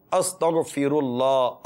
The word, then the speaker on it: استغفر اللہ: غاف نہیں ہے، غین ہے. استغنی، استغ، غین ہے. استغفر اللہ، استغفر اللہ، استغفر اللہ. یہ تلفظ درست کریں، حروف کی ادائیگی درست کریں تو اس کی برکتیں نصیب ہوں گی. اب کوئی غین کی جگہ پہ غاف پڑے گا تو لفظ ہی بدل گیا، معنی کیوں نہیں بدلے گا پھر؟